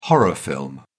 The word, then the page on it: horror film: British English